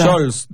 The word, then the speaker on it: Scholes: Scholes, del Manchester United.